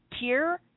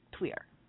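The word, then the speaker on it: Týr: I told you about this really awesome pagan rock, or like, you know, heavy metal hardcore band called Tyr, T-Y-R. Actually, it's pronounced Queer, like, you know, P-W-E-A-R, Tweer, you know, but with the Faranese accent.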